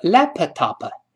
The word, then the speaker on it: laptop: 爆破了，那我们失去爆破，lap，憋住嘴，把上下唇憋住，闭一下，然后再读后面那个 top，跟老师慢慢读，laptop，再来一遍，laptop，不能读成 laptop，更不能读成 laptop，你不做这个动作也能听到，再来一遍，laptop，好，我说 my laptop。